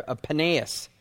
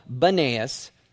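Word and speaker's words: Banias: Pan or Panias or Panaeus. And then it's later also called Benaeus, because the Arabs couldn't pronounce the word P, Panaeus, and so they call it Benaeus.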